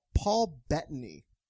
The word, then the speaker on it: Bettany: But the Paul Bettany, is that how you say his name? – Yeah.